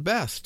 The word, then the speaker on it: Beth: In English, usually spelled Beth, B-E-T-H, pronounced Beth.